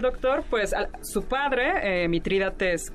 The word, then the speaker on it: Mitrídates: Es Mitrídates. – Así es. Ese, ahí está, Mitrídates, otro nombre.